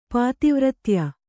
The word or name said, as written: ಪಾತಿವ್ರತ್ಯ